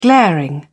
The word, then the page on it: glaring: British English